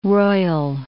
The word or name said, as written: roil